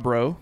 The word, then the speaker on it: Braeux: Rob Bro. If y'all haven't followed him yet, I think it's Rob Bro Show on Twitter, and Bro is spelled B-R-A-E-U-X.